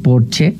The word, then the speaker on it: Porsche: O decir que tengo un Porsche en lugar de decir tengo un Porsche, porque en alemán Porsche se dice Porsche porque todas las es al final se pronuncian, como Marlene Dietrich, por ejemplo, que todo el mundo dice Marlene, pero es Marlene.